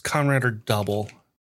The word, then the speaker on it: Kamen Rider W: See, the earlier series I've seen is Kamen Rider Double, which is spelled Kamen Rider W, but said Double is 2010.